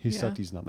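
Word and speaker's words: Hisatsinom: The Hopi called the Fremont people and forgive the pronunciation, but I've got Hisatisnam. – You guys want to challenge that? – Hisatisnam. – Hisatisnam. That's much better.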